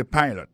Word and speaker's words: pilot: Pilot là, c'est même mot pilote là, il dit E-A-D-L-I, il écrit P-I-L-O-T. Alors, il s'est prononcé I dans le cas ça. Alors, et puis, dans le mot pilote là, tout, après accent tombé sur le premier syllabe là.